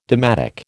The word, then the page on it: dalmatic: American English